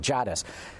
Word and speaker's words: Jadis: He's had me butcher his name enough that he sent in the phonetic pronunciation. Jadis.